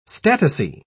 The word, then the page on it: statice: British English